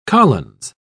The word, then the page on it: collins: British English